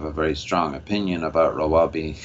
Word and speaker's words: Rawabi: Tell us about the planned community for affluent Palestinians, Rawabi, if I'm saying that correctly. – Yes. Rawabi is a very controversial city being built on a hill just north of Ramallah.